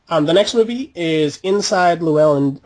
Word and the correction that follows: Llewyn: or I don't know if I'm ever pronouncing this right. – I think it's Llewyn.